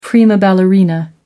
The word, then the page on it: prima ballerina: American English